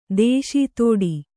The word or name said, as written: ದೇಶೀತೋಡಿ